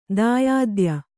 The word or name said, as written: ದಾಯಾದ್ಯ